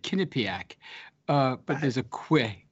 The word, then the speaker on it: Quinnipiac: uh, it's pronounced Quinnipiac, by the way, Quinnipiac. I grew up uh, near the Quinnipiac River in Connecticut.